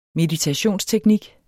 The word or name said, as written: meditationsteknik